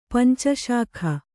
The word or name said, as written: ಪಂಚಶಾಖೆ